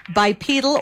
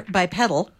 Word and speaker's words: bipedal: Bipedal or bipedal, depending on which pronunciation you go. – I've used both. – There's both online.